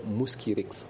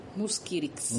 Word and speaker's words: Mosquirix: Le nom commercial sur lequel le vaccin va être mis sur le marché, c'est Mosquirix. – Mosquirix.